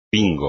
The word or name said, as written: pingo